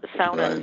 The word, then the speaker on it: sauna: and you had a sauna, or sauna in English, but sauna is the Finnish pronunciation, and you know, it's the same kind of idea that is to carve out reality.